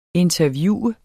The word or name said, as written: interviewe